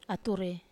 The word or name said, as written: ature